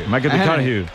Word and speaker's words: McConaughey: Matthew McConaughey. – Sí. – Hay que ir corrigiendo la pronunciación. – McConaughey.